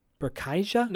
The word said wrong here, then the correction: Burciaga: No, it's Bursiaga. – Bursiaga. Yeah. Burciaga.